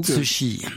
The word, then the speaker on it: Cixi: Vous prononcez ça comment, vous ? – Cixi. – Voilà, Cixi. J'essaierai de faire comme vous à l'avenir.